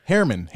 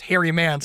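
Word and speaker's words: Herriman: I'm doing well. It's Harriman. – Harriman. Well, I think you're like, yeah, I'm getting better there. I thought, well, last time you pronounced, you told me to pronounce Harry Man, so I was close.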